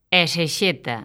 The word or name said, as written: esexeta